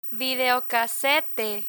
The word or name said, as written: videocasete